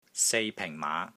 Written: SEI3 PENG4 MAA5

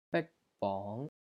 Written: BIK1 BONG2

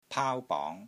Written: PAAU1 BONG2

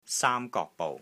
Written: SAAM1 GOK3 BOU6

